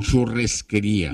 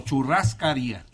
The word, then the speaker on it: churrascaría: Es churrascaría o churresquería. – No, churrascaría. De churrasco.